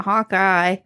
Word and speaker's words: Hawkey: It is spelled like hockey, not Hawkeye. – Yes, it's Hawkeye without the last E.